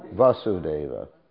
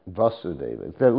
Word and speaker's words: Vasudeva: Listen to the pronunciation. There's two words. Vasudeva, try to listen. Vasudeva and Vasudeva. Does everyone hear the difference? One is a, I'll just re-emphasize it a lot. Vasudeva, Vasudeva. Okay, you see the difference?